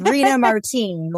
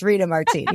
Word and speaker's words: Rena Martine: So it's Rena Martin, like Rena Martin. Drinking a martini in Reno, Nevada. So it's Rena Martin.